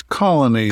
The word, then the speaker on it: Coligny: It's Colony is how they pronounce it usually in the show.